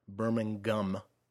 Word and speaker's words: Birmingham: Birmingham, I should say, because you pronounce the G when you uh, are from Brum.